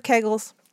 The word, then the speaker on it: Kegel: Have you heard of Kegels? – Yes. – Some people say Kegels. When I looked up the pronunciation, it said Kegel, which is what I've always said. It's what I was taught.